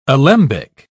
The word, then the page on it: alembic: British English